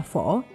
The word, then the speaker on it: phở: Quay lại với cái tên phở, thì từ phở hiểu nôm là món ăn chế biến từ lúa gạo phổ biến trong đại chúng và phát âm là phổ.